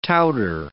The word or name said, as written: touter